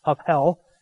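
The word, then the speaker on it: hevel: And havel in Hebrew, when you pronounce it that way, it's two breaths, havel, and you actually have to breathe out as you're saying it.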